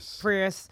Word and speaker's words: Prius: Prius.